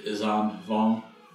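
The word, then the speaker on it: Yuuzhan Vong: The how would you pronounce it? I think it's the Izam Vong series. – Yes. That how you said it sounds perfect.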